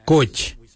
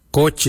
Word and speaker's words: goodj: Karli is spelled G-I-I-L-Y-I, karli. For the traditional stone axe, we say goj, goj. Goj is spelled G-O-O-D-J, goj.